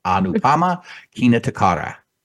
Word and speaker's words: Anupama Kinatukara: Anupama Kinatukara.